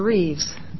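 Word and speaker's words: Rives: My name is Carol Reeves. My name is spelled R-I-V as in victory, E-S, and I am the administrator for the Board of Accountancy.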